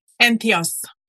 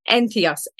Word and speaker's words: Entheos: and this year qualified as a celebrant with Ethnos Ireland. Did I pronounce that correctly? Entheos. Entheos. – Entheos yes.